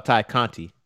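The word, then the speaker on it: Tay Conti: Um, was sorry if I butcher her name, Ty County. Is that how you said? – Uh, Ty Conti. – Ty Conti. Conti.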